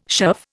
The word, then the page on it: shove: Indian